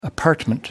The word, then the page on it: apartment: American English